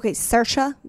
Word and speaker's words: Saoirse: Freaking out about, Saoirse, that's how to say it. I looked it up.